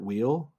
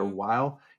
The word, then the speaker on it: Weill: And so, you know, the famous story is he when he came to the U.S., apparently he insisted on being his name being pronounced Kurt Weill, I think, or Weill. He didn't want to have a German pronunciation.